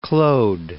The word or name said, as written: Claude